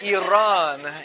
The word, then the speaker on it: Iran: It's like Iran. Where did you run? It's Iran, you know.